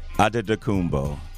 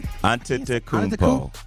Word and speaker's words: Antetokounmpo: Giannis, and how do you say it? – Antetokounmpo. – Antetokounmpo. Antetokounmpo. – Yes.